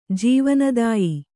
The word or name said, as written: ಜೀವನದಾಯಿ